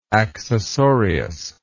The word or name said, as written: accessorius